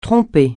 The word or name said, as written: tromper